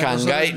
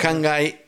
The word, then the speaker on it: Hanggai: Hangai, nosotros lo decimos castellanizadamente. – Por supuesto, claro. – Hangai Xiger Xiger.